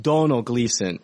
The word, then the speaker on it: Domhnall Gleeson: Which I know it looks like Domhnall Gleeson, everybody, but it's actually pronounced Donald Gleeson.